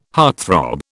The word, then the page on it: heartthrob: British English